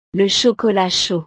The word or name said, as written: le chocolat chaud